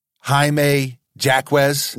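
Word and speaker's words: Jaime Jaquez: I think one of the best fits is Jaime Jacquez. – Yeah. – Hope I'm not butchering that name. – No, that's how you say it.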